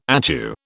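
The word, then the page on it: étui: British English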